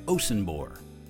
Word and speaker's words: Osunbor: And now, here is my conversation with Mr. Mo Osunbor of Facebook. – So, how do you say your last name? Osunbor? – Osunbor. But, Osunbor. You know, if we're back in Nigeria, yes, it's Osunbo.